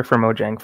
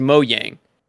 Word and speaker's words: Mojang: Because I thought it was Mojang. – I think you're right. I think I mispronounced it just now. – Mojang? – Yeah, it's a Y.